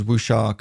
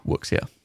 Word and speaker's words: wuxia: I was just a horrible Westerner just pronouncing all the letters. Yeah. But it's, yeah, it's wuxia.